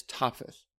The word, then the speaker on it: Topheth: Now, in scripture, we see an example of this place known as Topheth, or Tophet, depending on how they pronounce it.